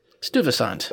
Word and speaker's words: Stuyvesant: Stuyvesant High School? I don't know. I'm going to throw it out here. You can... How do you pronounce that? – Mm. Stuyvesant.